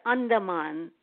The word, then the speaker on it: Andaman: It's actually A is like so it's Andaman. – Andaman.